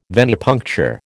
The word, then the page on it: venipuncture: American English